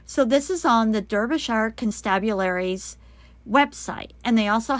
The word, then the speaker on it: Derbyshire: Now, it's all part of a highly successful forensic search dog project pioneered by the Derbyshire Constabulary. I looked it up. That's how you say it.